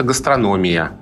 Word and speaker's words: гастрономия: А про гастрономию словари говорят, что это гастрономия. И только с таким ударением.